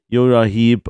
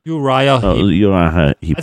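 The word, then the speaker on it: Uriah Heep: Jura Heap und Susi Quattro, wie wurde überhaupt ausgesprochen? Jura Heap, das ist ja eigentlich so deine? – Uriah Heap. Also ja.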